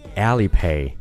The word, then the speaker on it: Alipay: 哎，不过在于这个词上，也有部分美国人会把它读成 Alipay，把前面的啊读成了 a，Alipay 啊。不过这个你知道就好，我们就按照中文里的阿里来读是完全没有问题的 Alipay。